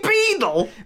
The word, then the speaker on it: Beadle: Is it Beadle? – Beadle? – He's called Jeremy Beagle. – I've been calling him Jeremy Beagle for years. – A beagle is a dog. – He's called Jeremy Beadle.